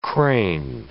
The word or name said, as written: crane